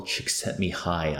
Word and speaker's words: Csikszentmihalyi: Mikhail Csikszentmihalyi, I think's how it's pronounced.